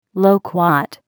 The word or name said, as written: loquat